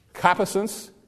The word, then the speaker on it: concupiscence: C-O-N-C-U-P-I-S-E-E-N-C-E. Concupiscence. It's that word there in verse 4. C-O-N-C-U-P-I-S-C-E-N-C-E.